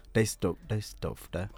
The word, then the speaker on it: Düsseldorf: Düsseldorf.